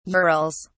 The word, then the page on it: urals: British English